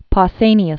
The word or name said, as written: Pausanias